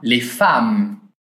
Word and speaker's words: femme: « Femme » s'écrit avec un « e », mais on le prononce comme un « a ». Les femmes.